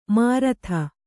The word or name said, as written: ಮೂರುತಿ